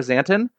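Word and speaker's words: Szanton: Zanton.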